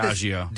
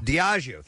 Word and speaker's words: Diageo: Diageo, is this how you say it? – Diageo. – Diageo, thank you very much.